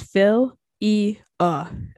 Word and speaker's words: philia: So today we're going to talk about philia, kind of love, and the pronunciation is phil-e-a. Okay, so there you go, philia.